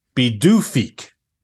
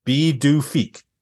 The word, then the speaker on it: Pituffik: Well, I'm going to spell it first. Its new name is Pituffik, and it's pronounced Pituffik. Pituffik.